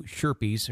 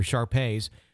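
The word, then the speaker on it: Shar-Pei: along with my two Sherpies, or Sharpays, so you can pronounce it.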